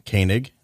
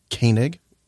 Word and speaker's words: Koenig: Koenig. What? Koenig. What? Koenig. What? K-O-E-N-I-G.